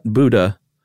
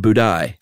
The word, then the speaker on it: Budai: 10th century Chinese monk whose name was not Buddha, but Budai.